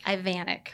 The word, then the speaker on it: Ivanek: We say it like Americans, just Ivanek. – Ivanek.